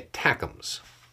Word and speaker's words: ATACMS: One of the things that was very sought after was the ATACMS, longer range versions of it, the ATACMS, favored pronunciation there.